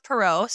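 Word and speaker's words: Perrot: But I think it's pronounced Perot State Park.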